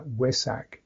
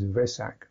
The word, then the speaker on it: Vesak: So what happens is that the Thais will tend to pronounce what we would see as a V, as a W, like Wesak. The Pali would have it as Vesak, Vesak. Slightly soft, very soft, soft V, Vesak.